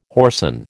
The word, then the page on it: whoreson: American English